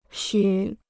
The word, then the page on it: 虚: xū